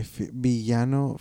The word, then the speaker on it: villano: Viviano, is that how you say it? Yeah. – What? – Viviano favorito, my favorite villain. – My villano favorito.